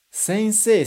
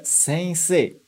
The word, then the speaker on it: sensei: A gente escreve esse i no final, mas na verdade a gente não fala. Presta atenção na minha pronúncia. Sensei. Sensei. Reparou? Eu falei, na verdade, como se fossem dois E's no final.